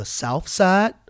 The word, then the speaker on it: South Side: We call it the South Side like 2 Chainz likes to pronounce it and everybody else who's from there in the hip-hop culture. Mm-hmm. South. S-O-U-F. Side.